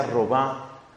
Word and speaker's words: Robin: Se escribe Robin, se pronuncia Robin.